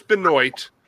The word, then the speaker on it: Benoit: Benoit. – I heard he lost a wrestling match to a gym machine. – Benoit? – I don't know how you get Wah out of O-I-T. – How do you get Wah out of R-O-Y? – That's Roy.